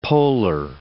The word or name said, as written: poller